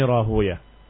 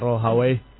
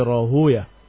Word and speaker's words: Rahuyah: Wa amma Ishaq fahuwa Ishaq Ibn Ibrahim Ibn Mukhallad Abu Ya'qub Al-Hanbali An-Nisaburi Al-Imamul Ma'ruf Ibn Rahuya Ishaq Ibn Rahuya wa hakada yuqra. Walaupun sebagian membaca Rahawai seperti Sibawai seperti Naftawai, tapi sebagian mereka membacanya bukan Rahawai tetapi Rahuya.